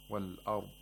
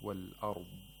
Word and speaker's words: والأرض: Wal-ard is incorrect. It's wal-ard. So you hear this sound at the end, the stretching or the lengthening of the consonant of Dad.